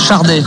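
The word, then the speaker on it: Sade: Et normalement, ce qui est assez marrant, c'est qu'en Angleterre, aux États-Unis, j'ai appris ça par Ginny, par contre, ça se prononce Chardé, avec un R qui n'existe pas, mais on dit Chardé normalement.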